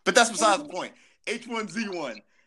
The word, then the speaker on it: H1Z1: The game he's gonna talk about is H1Z1? – Yes, you said it right.